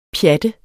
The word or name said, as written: pjat